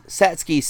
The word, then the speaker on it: Satsuki: What is it? Sasuke? – It's Satsuki, isn't it? – Yes, but it's spelled Satsuki.